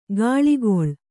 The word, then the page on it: ಗಾಳಿಗೊಳ್: gāḷigoḷ